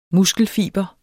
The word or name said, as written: muskelfiber